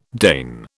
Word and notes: deign: British English

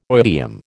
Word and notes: oidium: American English

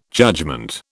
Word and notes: judgement: British English

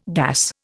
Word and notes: gas: Indian

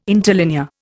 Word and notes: interlinear: Indian

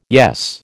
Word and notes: yes: American English

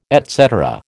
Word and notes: et cetera: American English

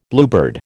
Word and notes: Bluebeard: American English

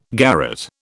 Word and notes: garret: British English

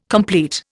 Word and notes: complete: Indian

